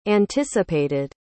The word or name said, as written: anticipated